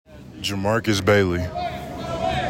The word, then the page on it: Jer’Marques Bailey: juh MARK iss BAY lee